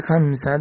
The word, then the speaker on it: Hamsa: Entonces el maestro enseña en esa conferencia de que el Hamsa debe pronunciarse Ham Mental Inti, Ham más largo que el Sa. Así dice el maestro, que con esa técnica, así es como se debe pronunciar Ham más largo y Sa un poco más corto.